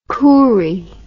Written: Koorie

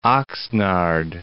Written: Oxnard

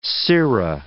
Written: sirra

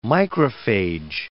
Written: microphage

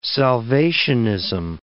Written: salvationism

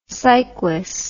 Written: siquis

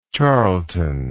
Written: Charleton

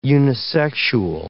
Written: unisexual

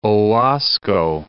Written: Owasco Lake

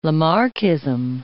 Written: Lamarckism

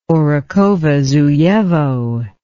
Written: orekhovozuyevo

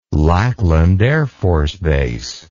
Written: Lackland Air Force Base

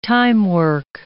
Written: timework

